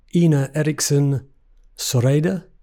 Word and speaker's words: Ine Eriksen Søreide: This is, and you can help me with my Norwegian pronunciation here, the Norwegian Foreign Minister, Ine Eriksen Søreide. – I think you did a brilliant job.